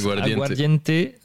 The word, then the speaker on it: aguardiente: On goûte donc l'aguardiente. C'est comme ça que ça se prononce. – Aguardiente.